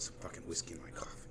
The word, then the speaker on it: Coffey: So if you're not familiar with coffee grain, it's spelled C-O-F-F-E-Y, and it's capitalized.